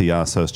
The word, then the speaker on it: Iasos: Also, Pass the Paintbrush listeners, if you're not already into Yassos, check him out. It's spelled IASOS.